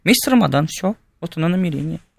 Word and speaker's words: Рамадан: В арабском языке произносится как Рамадан. Прям с таким твердым. Да, Рамадан. У персов оно произносится как Рамазан. Вот эту букву они произносят как букву З.